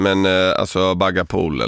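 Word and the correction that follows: baghali polo: eller vad det heter. – Bagliapolo.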